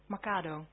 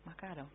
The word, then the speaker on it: Makado: Met name ook als het gaat om het winkelcentrum Makado, ik weet nog steeds niet waar je de klemtoon moet leggen. Makado. Ah, oké. Okay. Makado, dank u wel.